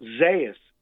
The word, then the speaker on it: Zayas: Alex is now clear that Zayas is the way you pronounce it. – Yes, I'm sure. – To rhyme with Amadeus.